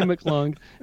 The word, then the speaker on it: McClung: Yeah, Andy McClung.